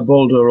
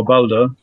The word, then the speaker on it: Baldur: Baldur, is that correct? – Yeah, Baldur or Balder.